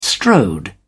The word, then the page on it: strode: British English